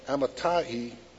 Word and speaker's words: Amittai: In verse 1, chapter 1, he said, "Now the word of the Lord came to Jonah, the son of Amittai," believe it or not, that's the way it's pronounced, "Arise, go to Nineveh, that great city, and cry out against it, for their wickedness has come up before me."